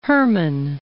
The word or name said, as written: Herrmann